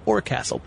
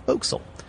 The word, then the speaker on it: forecastle: Oh, it's spelled forecastle, but it's pronounced forecastle.